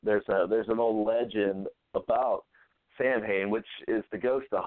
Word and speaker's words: Samhain: Samhain is also known as Samhain. It's pronounced in Gaelic.